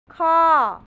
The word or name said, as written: khor